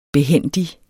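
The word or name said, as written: behændig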